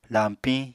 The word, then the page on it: lañpiñ: apatani